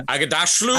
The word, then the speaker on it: Aghdashloo: Um, Aghdashloo.